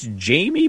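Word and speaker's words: Jamey: And I think it's Jamie, but it's spelled Jamay Chadwell,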